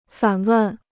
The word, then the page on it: 反问: fǎn wèn